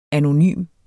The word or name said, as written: anonym